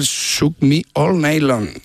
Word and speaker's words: Shook Me All Night Long: Lo has dicho en francés. – O sea, has cambiado cuatro palabras de sitio. – Has engolado tanto que se te ha pasado de idioma. – Qué grande, tío. – Me cuesta el Shook Me All Night Long. – Ahí mucho mejor. Muy bien. Parece un plato un tailandés, ¿eh?